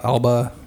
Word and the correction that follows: Idris Elba: Idris Elba.